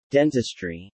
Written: dentistry

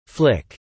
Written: flick

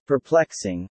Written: perplexing

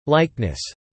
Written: likeness

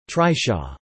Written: trishaw

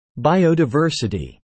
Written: biodiversity

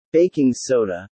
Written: baking soda

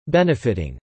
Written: benefitting

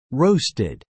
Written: roasted

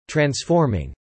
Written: transforming

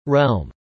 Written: Realm

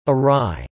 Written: awry

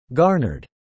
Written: garnered

